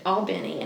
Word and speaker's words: Albany: And so anyway, he came back home to Tallahassee from this interview, and he was like, everybody up there pronounces it Albany. And I was like, I could have told you that. like, like, that is exactly how you're supposed to pronounce yeah, it.